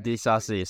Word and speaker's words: Disasi: It is Disasi. – Oh, Disasi. – Don't Disasi me. All right, Disasi. Let me do it in my American accent, please. – Okay, right. Disasi.